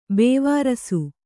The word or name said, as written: ಬೇವಾರ್ಸಿ